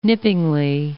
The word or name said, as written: nippingly